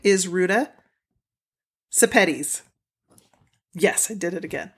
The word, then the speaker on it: Sepetys: And then she says, a good way to remember how to pronounce my last name is to say spaghettis, because it rhymes with spaghettis. Sepetys. There you go. – Spaghettis. – Sepetys.